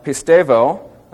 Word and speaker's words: pistevo: So we have pistevo, and so we have the word believing from pistevo, P-I-S-T-E-V-O, long O there.